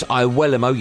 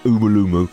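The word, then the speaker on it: Iwelumo: We'll also hear from the former Scotland striker Chris Iwuelamo, yeah, that's how you pronounce it, not Umulumu, and the chances of Celtic giving their old firm rivals a guard of honour.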